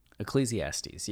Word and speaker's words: Ecclesiastes: and that the line references Ecclesiastes. Did I say that right this time? – Ecclesiastes, yeah.